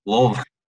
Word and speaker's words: Louisville: Louisville. – You've got to say it like you've got marbles in your mouth.